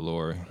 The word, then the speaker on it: Lori: How you say it, Lori? – I call it Lori.